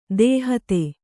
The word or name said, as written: ದೂಹತ್ತಿ